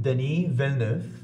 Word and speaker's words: Denis Villeneuve: I've noticed that Denis Villeneuve, that's how you pronounce it, Denis Villeneuve's films are a slow burn but not confusing.